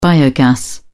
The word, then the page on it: biogas: British English